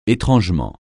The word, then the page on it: étrangement: français